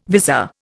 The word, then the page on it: vizor: Indian